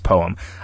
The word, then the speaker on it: poem: Poem is one word, like home. How do you pronounce poem? I pronounce it as two syllables, absolutely.